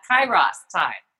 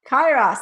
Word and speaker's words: Kairos: Okay, so you talk about something called Keros time. Am I saying that right? I, it's actually I had to look up the pronunciation for that Kairos time. – Kairos, ah, awesome.